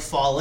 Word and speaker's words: Fale: Yeah, there's this this monster named Bad Luck Fale. Did I pronounce that right? – Yes. – Because it looks like Foley.